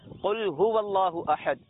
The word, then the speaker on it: قل: یہ صحیح تلفظ کے ساتھ کہ قاف کو موٹا پڑھا جائے گا، قل ہو اللہ احد، کہو